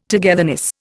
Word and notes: togetherness: Indian

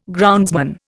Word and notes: groundsman: Indian

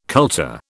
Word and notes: kultur: British English

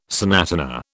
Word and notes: sonatina: British English

